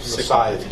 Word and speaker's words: Scythe: Yeah, S-I-S-C-Y-T-H-E, right? – Yeah, just like the... – Oh, Scythe. – Scythe, yeah.